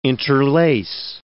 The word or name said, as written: interlace